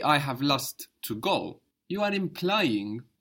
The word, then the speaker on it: Lust: And lust is the same word in English and German, lust. So how would you say that? I have lust. – Ich habe Lust. – Good. Ich habe lust, or ich hab lust.